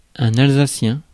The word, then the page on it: alsacien: France (Île-de-France); [œ̃n‿al.za.sjɛ̃]; /al.za.sjɛ̃/